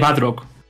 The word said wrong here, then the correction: Bardock: Bardock, el padre de Son Goku.